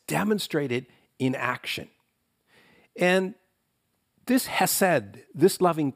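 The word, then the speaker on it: hesed (חסד): Well, this idea of loving kindness in Hebrew is expressed with the word hesed, H-E-S-E-D, hesed.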